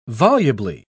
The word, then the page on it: volubly: British English